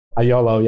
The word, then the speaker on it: IOLO: We saw you acquired companies like, you know, YOLO or IOLO. I don't know if you actually pronounce it YOLO. I put that in there. – IOLO, yeah.